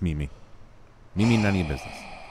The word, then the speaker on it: Mimi: How do you say her name? Mimi? M-I-M-I. Mimi? – Mimi.